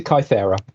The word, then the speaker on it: Kythera: We pronounce it Kythera, but opinions differ.